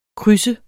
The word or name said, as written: krydser